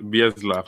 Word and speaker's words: Bielsa: I think you'll pronounce it Bielsa. – Bielsa.